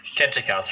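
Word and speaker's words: Khentkaus: Can we talk about one of the earliest subjects on your show, which was Queen forgive me if I'm butchering this, Queen Kentikaus? – Kentikaus from the 4th dynasty, yeah.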